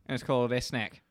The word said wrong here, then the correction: Ekans: Ekans. – Ekans. Sorry, Ekans. – Everyone's saying it wrong but me.